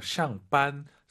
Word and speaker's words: 上班: 아, 이것도 정말 많은 분들께서 샹반, 쌍비읍으로 많이 해요. 샹반. 아, 물론 되는데 힘 빼시고 우리 왕 선생님처럼 샹반, 퇴근하다.